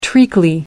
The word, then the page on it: treacly: American English